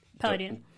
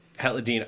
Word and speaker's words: Palladino: And it I mean, I know that Amy... – Palladino. – Palladino. Okay, I wasn't sure quite how to pronounce it.